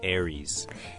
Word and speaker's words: Aries: first one, that would be Aries.